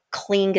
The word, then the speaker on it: Kling: But yeah, April Kling Meyer, if you Google my name, you will find me, no problem. Just remember, Kling is spelled with a K.